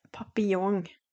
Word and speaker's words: papillon: Jeg måtte faktisk ringe til en fransk eh, venninne og spørre hvordan uttaler du papillon, sommerfugl, på fransk, så Sigurd, det uttales papillon. Papillon. Og det er litt viktig å uttale det kanskje riktig, da.